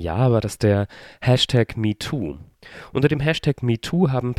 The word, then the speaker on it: MeTwo: Und plötzlich gibt es einen neuen Hashtag, ein Hashtag, der letzte Woche auf Twitter viral ging, der genauso klingt wie der alte Hashtag, nämlich auch MeToo ausgesprochen wird, sich aber anders schreibt, nämlich nicht M-E-T-O-O, sondern M-E-T-W-O, also das englische Zahlenwort 2 ist drinne, heißt aber im Endeffekt auch ich auch.